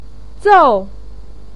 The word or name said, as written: zou4